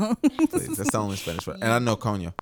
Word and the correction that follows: Coño: I don't even think you say that right. – Coño. Did I say it right?